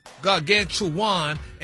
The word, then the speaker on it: gargantuan: It's pronounced gargantuan. – Yeah, now you're in the neighborhood. – Close. – There you go. Come on. – How in the hell you get gargantuan and it's gargantuan? G-A-R, gargan, G-A-N, tuan, T-U-A-N.